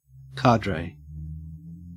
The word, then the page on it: cadre: Australian English